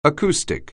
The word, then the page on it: acoustic: anglais